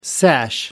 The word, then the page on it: sash: American English